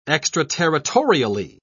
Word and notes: extraterritorially: British English

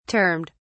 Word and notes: termed: British English

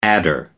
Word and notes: adder: American English

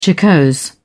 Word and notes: jocose: British English

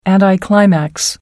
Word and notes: anticlimax: American English

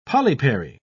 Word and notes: polypary: British English

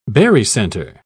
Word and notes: barycenter: British English

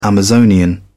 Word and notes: amazonian: British English

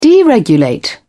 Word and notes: deregulate: British English

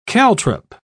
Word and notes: caltrop: British English

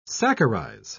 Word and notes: saccharize: British English

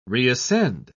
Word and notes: reascend: British English

